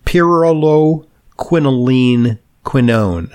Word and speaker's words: pyrroloquinoline quinone: Uh, something called PQQ, and let's see if I get the pronunciation right. It's pyrroloquinoline quinone. How am I doing? – That's great. It's it's kind of a redundant sounding word, though.